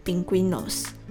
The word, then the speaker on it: pingüinos: 那我们就先从第一个句子开始：Somos la familia de los pingüinos。那这里的生字应该就是 pingüinos，企鹅，就是动物的企鹅。那如果大家有看说明栏的话，会发现单字里面的 u 上面多了两个小点，那就表示。呃，G U I 的 U 是有发音的，所以是念 pingüinos。